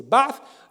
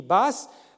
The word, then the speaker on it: Baath: En arabe, il faut prononcer Baath. Je sais qu'en français, on l'écrit Baas, mais c'est une... Parce que certains orientaux, d'ailleurs, prononcent le S à la place du TH, mais c'est pas...